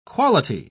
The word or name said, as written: quality